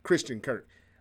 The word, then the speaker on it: Christian Kirk: Only thing I know is they paid Tristan Kirk a lot of money, uh, whatever his name is, Christian Kirk. I think I said that right.